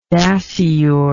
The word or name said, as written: dasyuroid